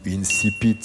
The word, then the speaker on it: incipit: Quel est l'incipit ou l'incipit, je crois que les deux prononciations sont autorisées.